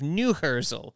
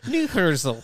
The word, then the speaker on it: Neuherzl: Yeah, it's spelled N-E-U-H-E-R-Z-L. Neuhersel. – Neuhersel.